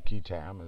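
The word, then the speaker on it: qui tam: Many years ago, the Lincoln Law was what came, you can call it quitam or quitam, as some people pronounce it, is a Latin term, and it's short for he who brings a lawsuit on behalf of himself and the king.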